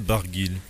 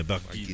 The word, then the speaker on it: Barguil: Warren Barguil. – Yeah, you do pronounce the L at the end. There's a little L. – Yes, we do. Yeah, Barguil.